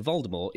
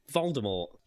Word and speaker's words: Voldemort: Anyway, J.K. Rowling previously stated that the T in Voldemort is silent, as it is in the French word for death, more. Jim Dale pronounced it so in the US audiobooks that came before the release of the movie, where the characters pronounced the T.